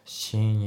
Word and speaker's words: seeing: で、あと発音なんだけど、 seeing の G がね、ほぼ抜けてますね。 seeing you ってなってね。 seeing you じゃなくて、 seeing you ですね。